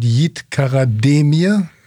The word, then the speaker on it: Yiğit Karademir: Ich hätte jetzt gesagt Yiğit Karademir. – Ja, das ist richtig, ohne das G. – Okay. Und auch die Betonung bei Karademir auf dem D oder ist es Karademir? – Ja, also das erste war schon richtig.